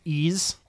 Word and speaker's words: Ys: Yeah, yeah, I think it's, the thing is pronounced Ys. – Ys? – Yeah, because it's Y-S.